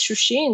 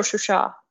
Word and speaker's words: Shushi: and then moved towards Shushi. And Shushi is Shushi or Shusha, if we go with the Azerbaijani pronunciation, is very, very important, culturally for both nations.